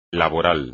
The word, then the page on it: laboral: Spanish